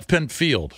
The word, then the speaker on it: Pinfield: Pinfeld, man, I just went to his... – Pinfield. – A Pinfield. I went to his Wikipedia. – I always call him Pinfeld. – That could be wrong. – It's spelled... – It is spelled Field. – It's spelled Field. – Oh, okay.